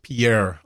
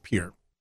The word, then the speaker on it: Pierre: And we know they're going to pronounce it correctly, right? Pier. – No, yeah. – Well, I've heard people on the East Coast calling it the USS Pierre already, but it's going to be the USS Pier.